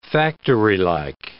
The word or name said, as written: factorylike